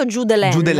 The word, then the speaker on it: Judeline: Julen, che abbiamo dovuto googolare bene come si pronunciava, perché è scritto Judelaine. – Judelaine, sì.